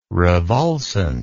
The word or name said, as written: revulsant